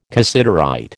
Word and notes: cassiterite: American English

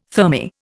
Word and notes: thermae: Indian

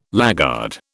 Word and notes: laggard: British English

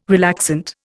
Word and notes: relaxant: Indian

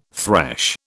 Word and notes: thresh: British English

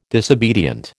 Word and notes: disobedient: American English

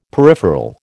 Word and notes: peripheral: American English

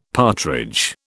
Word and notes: partridge: British English

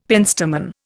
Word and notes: penstemon: Indian

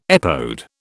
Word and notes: epode: British English

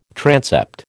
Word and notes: transept: American English

